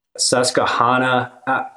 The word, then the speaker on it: Susquehanna: Susquehanna.